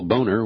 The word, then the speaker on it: Boehner: And now they continue to posture, pontificate, and John Boehner, who some people call Boehner, which we believe is the accurate German pronunciation, he threw a little tantrum on the floor of the House today before all Republicans once again voted against this package.